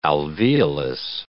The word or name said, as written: alveolus